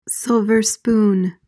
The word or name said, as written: silver spoon